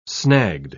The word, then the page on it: snagged: British English